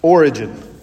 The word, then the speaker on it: Origen: It kind of looks like Oregon, but it's pronounced Origen.